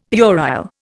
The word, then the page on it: puerile: Indian